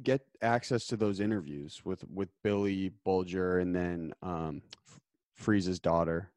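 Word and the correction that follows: Geas: Am I saying his name right? Freeze? – Freddie Gius.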